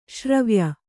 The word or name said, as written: ಶ್ರಾವ್ಯ